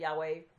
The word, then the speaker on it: Yahweh: Well, the word is actually Y-W-H, with vowel pointings that they've lost, which is why we don't know how to pronounce it. We pronounce it Yahweh. That's the best that we can come up with.